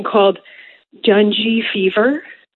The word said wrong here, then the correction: dengue: Oh, dengue. You mean dengue fever.